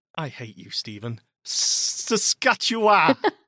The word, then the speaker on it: Saskatchewan: I live in Regina, Saskatchewan.